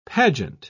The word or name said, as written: pageant